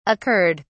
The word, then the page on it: occurred: British English